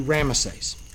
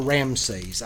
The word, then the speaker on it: Ramesses: Now, it's either Ramesses or Ramses. I've seen it spelled R-A-M-A-S-E-S or R-A-M-S-E-S. I don't think it matters. It's either one. It's either Ramesses or Ramses. I've heard it pronounced and spelled both ways.